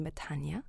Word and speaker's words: metanoia: Quite early on in the process, I came across the word metanoia, or as one Greek lady pronounced to me, metanoia, although I know I'm probably perverting it.